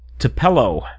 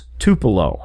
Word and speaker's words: Tupelo: It was, you pronounced it Tupelo, and it was Tupelo.